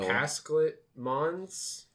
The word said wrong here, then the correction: Pashalik Mons: Pashalik Mons.